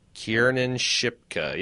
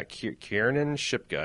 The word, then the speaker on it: Kiernan Shipka: I'm going to butcher her name. It's like Kierna Shipka or something. – Okay. – Oh, you don't know either? I thought you were going to be able to correct me. – No, I don't. Sorry. – Yeah, I have a terrible, terrible name, time. Kiernan Shipka. Yeah, Kiernan Shipka, I think is how you pronounce it.